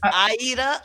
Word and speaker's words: Siedah: It's Aida. It's like the opera Aida.